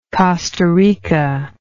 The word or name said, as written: costa rica